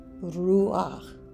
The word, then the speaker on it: Ruach: You would pronounce it R-U-A-C-H. Ruach.